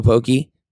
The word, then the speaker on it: poke: poke spot down there is called Ono Poke. Poke is the correct way to say it. – We're staying here. It sounds pretentious, but poke.